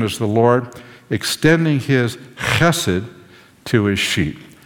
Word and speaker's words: chesed: So the H is a rough H. Hate. Chesed. Can you say that? Chesed.